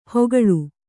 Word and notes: ಹೊಗಳು: Kannada

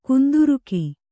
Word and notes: ಕುಂದುರುಕ: kunduruka

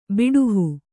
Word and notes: ಬಿಡಿಹೂ: biḍi hū

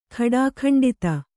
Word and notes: ಖಡಾಖಂಡಿತ: khaḍākhaṇḍita

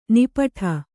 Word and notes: ನಿಪ್ಪಿಟ್ಟು: nippiṭṭu

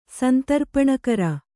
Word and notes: ಸಂತರ್ಪಣಕರ: Kannada

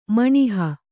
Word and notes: ಮಿನಾಹಿ: mināhi

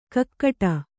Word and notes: ಗೂಗಿಡು: Kannada

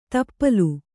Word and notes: ತಪ್ಪಲು: Kannada

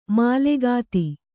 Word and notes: ಮಾಲೆಗಾತಿ: mālegāti

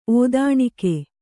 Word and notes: ಔದನಿಕ: Kannada

